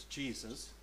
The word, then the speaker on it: Jesus: So his name, his first name is pronounced Jesus, but it's Jesus, and then his last name is Palomo, which means is Spanish for dove.